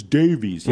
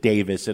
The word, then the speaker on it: Davies: We get people saying, it's Davies. Yeah, but the man himself, when we chatted with him, chose to pronounce it Davis, and I will.